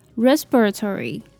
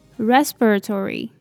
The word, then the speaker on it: respiratory: 音，那最后的 S-I-O-N 念起来比较像是忍的音，那重音放在第二个音节念作 confusion, confusion。接着是 anxiety, 我们先来看前面的 A-N, 在这里它念作 N, N, 后面的 X-I-E 念作 zire, zire, 那最后的 T-Y 为 T,然后重音放在第二个音节 anxiety, anxiety, 那最后是最难念的一个字 respiratory, respiratory,